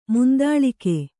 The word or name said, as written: ಮುಂದಾಳಿಕೆ